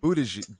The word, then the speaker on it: Buttigieg: Buttigieg.